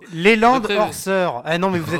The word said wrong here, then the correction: Leland Orser: Mais je le prononce mal, c'est Leland Orser, Voilà.